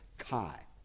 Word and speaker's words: chi: The X is the Greek letter chi, which is pronounced CH, hard CH or soft CH, chi.